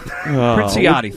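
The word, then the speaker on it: Princiotti: Nora Pranassi. I think I'm saying that right from the Boston Globe. How to say that again? Princiati.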